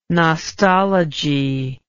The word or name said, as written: nostology